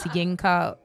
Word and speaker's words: Yinka: And so just Yinka, as it's spelled like Yinka, then oh shit, Elu.